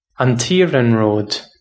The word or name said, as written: an t -iarnród